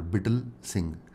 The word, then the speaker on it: Bittal: It's like brittle without R, Bittal Singh.